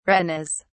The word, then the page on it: retinas: British English